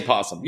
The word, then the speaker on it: opossum: It's spelled opossum, but you say possum.